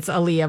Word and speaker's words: Aaliyah: That the way it's spelled is really, it always looks like Aaliyah. But yeah, it's Aaliyah, but it looks like Aaliyah.